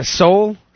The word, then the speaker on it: Seoul: She threw nearly $20,000 of cash into a downtown, I don't know, a Seoul walkway. – Yeah, that's how it's pronounced.